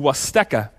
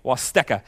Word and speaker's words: Huasteca: One other trip we're taking to Mexico is to Huasteca, not spelled with a W or an O, by the way, spelled with an H, very confusing, H-A-U-S-T-E-C-A, Huasteca.